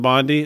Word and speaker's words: Bondi: I'm a little confused with the Attorney General of Florida, Pam Bondi. Is that, mm-hmm. is that right? – I do believe that's the correct pronunciation.